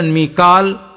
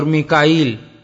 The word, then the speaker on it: میکائیل: کہ اہل عرب میں عبرانی اسما کے مختلف تلفظ رائج تھے مثلا میکال اور میکائیل اور میکائین ایک ہی فرشتے